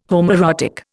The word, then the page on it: homoerotic: Indian